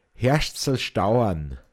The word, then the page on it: Hoadachbe-isn: Pinzgauer Mundart